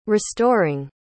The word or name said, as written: restoring